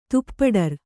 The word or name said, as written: ತುಪ್ಪಡರ್